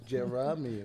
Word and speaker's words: Jeremy: Somehow the A is silent and I don't even have it in my name. It's Jeremy.